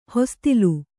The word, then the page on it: ಹೊಸತಿಲ್: hosatil